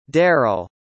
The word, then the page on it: daryl: American English